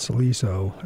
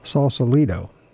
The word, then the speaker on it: Sausalito: The second tune, How Deep Is the Ocean, was recorded at the Trident Club in South Sausalito. I said it wrong again. Sausalito, California, and had Chuck Israel on bass and Larry Bunker on drums.